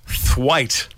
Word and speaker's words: thwaite: Today's word is thwaite. – Thwaite. – Thwaite. – Thwaite. – And it's spelt the way you think it is. – Like Thistlethwaite. – Yes. – Like Ray Thistlethwaite from Thirsty Merc.